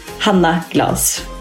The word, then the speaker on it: Hanna Glas: Okay, I'll say this with a bit Swedish accent, because my name is obviously Swedish too. Hanna Glas. There you have it. Simply and plain.